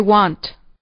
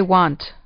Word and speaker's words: want: I want. – تلفظ اول این لغت به شکل و تلفظ نمی شود، بلکه باید لبها را به هم نزدیک کنید، مثل و. بگویید من می خواهم. – I want.